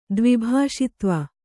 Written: ದ್ವಿಭಾಷಿತ್ವ

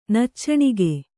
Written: ನಿಚ್ಚಣಿಗೆ

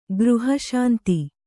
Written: ಗೃಹಶಾಂತಿ

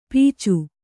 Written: ಪೀಚು

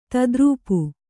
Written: ತದ್ರೂಪು

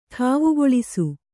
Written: ಠಾವುಗೊಳಿಸು

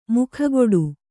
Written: ಮೊಗಗೊಡು

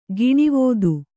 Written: ಗಿಣಿವೋದು

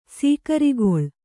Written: ಸೀಕರಿಗೊಳು

